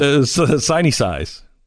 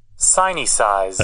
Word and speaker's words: Sinicize: Sinicize. So, but it's in this. Yes, I guess they're trying to. – How do you pronounce it again? – Sinicize.